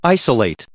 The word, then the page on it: isolate: verb